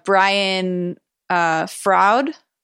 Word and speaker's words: Brian Froud: And I mean it's different tastes, but to me, I didn't realize that Brian Froud, is that how you say it? – Yep.